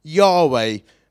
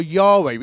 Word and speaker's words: Yahweh: But the Jews held YHWH or Yahweh, we think they pronounce it like that, with such reverence, they didn't speak his name.